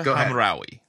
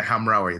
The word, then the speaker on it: Hamraoui: Kira Hamraoui. – Yeah, Hamraoui.